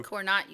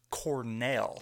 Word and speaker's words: Cornell: So Cornot University. – Cornot, yeah. You know, anybody that watches The Office, it's pronounced Cornell.